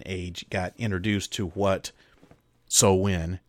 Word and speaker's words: Samhain: As a matter of fact, it's not even Samhain. It's pronounced Samhain, as I've already said, and it's not a Celtic god.